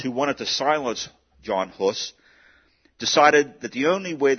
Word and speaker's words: Hus: His name was John, we would say Hus, H-U-S. It's actually pronounced Hus.